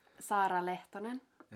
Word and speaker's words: Saara Lehtonen: How do you say your full name? – Sara Lehtonen.